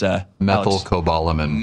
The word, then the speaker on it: methylcobalamin: Made with methocobalamin. Darn it, I thought I could do it. I only know how to pronounce that because methylcobalamin. – Methocobalamin. – Methylcobalamin. – Methylcobalamin.